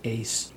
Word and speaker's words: ACE: A-S-E, Ace Portraits. Well, sort of like pronounces ACE, I would think. – Yes. Well, I didn't know that you said it as a word as opposed to just the letters.